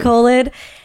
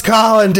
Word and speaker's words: Colin: when he was baptized, that's how the priest pronounced it. They kept saying colon. Colon. colon. – yes. – You're like, no, it's Colin, damn it.